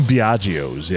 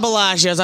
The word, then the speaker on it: Biagio's: From Biagio's, yes. – I always call it Bellagio's. I'm Italian, so, and it's bad. I say it wrong.